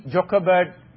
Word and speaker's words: Jochebed: If you see Jochebed, usually in Hebrew, J is pronounced as Y.